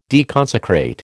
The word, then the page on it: deconsecrate: American English